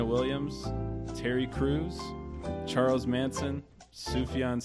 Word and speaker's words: Sufjan: Serena Williams, Terry Crews, Charles Manson, Sufjan Stevens.